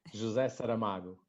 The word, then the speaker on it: José Saramago: José Saramago.